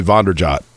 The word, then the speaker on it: Vanderjagt: Mike Vanderjagt, for example, when he first got to town, Mike Vanderjagt was like, well, my last name is actually Vonderjot. Vonderjot is how I pronounce it.